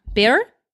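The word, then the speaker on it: Béart: Emmanuel Barre. I don't know. Barre? – Yes. – Yeah, I think the T is silent.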